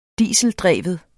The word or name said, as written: dieseldreven